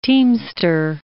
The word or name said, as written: teamster